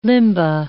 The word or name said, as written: limba